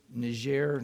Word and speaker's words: Niger: He's been doing work in Niger. Rhymes with Niger, not tiger. For those, it's Niger.